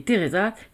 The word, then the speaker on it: Treasa: Monty, Amal, Mère et Teresa, quatre agents légèrement stéréotypés. – Treasa. – Ouais, alors ça s'écrit Treasa, mais t'es d'accord qu'ils l'appellent Teresa.